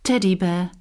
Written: teddy bear

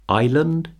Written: island

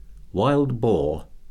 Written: wild boar

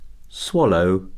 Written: swallow

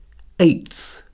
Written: eighth

